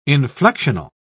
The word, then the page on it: inflectional: British English